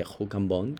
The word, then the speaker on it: Rue Cambon: de Rue Cambon, como se pronuncia en francés,